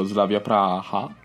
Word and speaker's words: Slavia Praha: invece è un bravissimo ragazzo che viene dallo Slavia Praha, si pronuncia così, sì.